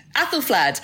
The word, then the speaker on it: Æthelflæd: Æthelflæd.